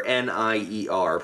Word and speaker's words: Nier: The spiritual successor to the game called Nier, NIER, pronounced Nier.